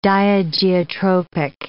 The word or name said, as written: diageotropic